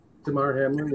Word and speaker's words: Damar Hamlin: That's Damar Hamlin. I hope I'm pronouncing the first name correctly. – Yeah, it looks like you are, Damar Hamlin.